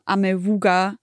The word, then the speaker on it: Amewu: Ich nehme Amewuga. Eigentlich wird er Amewu ausgesprochen, hat er mir gesagt.